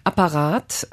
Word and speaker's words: Apparat: Apparat hast du mitgebracht? Spricht man die so deutsch aus? – Ja.